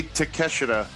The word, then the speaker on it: Takeshita: I'm trying not to say Takeshita, but that's how it's spelled. But. – Hey, Excalibur said Takeshita. – And Excalibur is probably right, but... I'm using the American pronunciation because I can't speak Japanese.